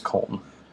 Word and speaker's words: Köln: K-O with an umlaut, L-N. I believe it's Köln.